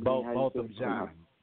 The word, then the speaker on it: Botham Jean: Botham Jean.